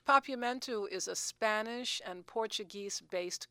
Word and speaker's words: Papiamentu: Papiamentu. – Papiamentu. Just like it sounds, just like it's spelled.